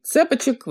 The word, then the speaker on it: цепочек: Во-первых, обновил сумки, в том числе знаменитую, но подзабытую Антигону, Э, во-вторых, вывел на рынок новый вид цепочек, вот именно так, с ударением на первый слог.